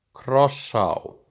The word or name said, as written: Krossá